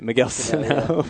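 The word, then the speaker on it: Miguel Sanó: And now that Miguel Sano, right, I said that right, Miguel Sano.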